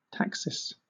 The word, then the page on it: taxis: British English